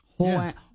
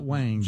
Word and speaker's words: Huang: Jensen Huang. – Yeah. – Excuse me? – Huang. – Not Wang.